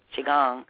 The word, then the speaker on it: Qigong: like you just mentioned with Qigong, I said that right? – Yep.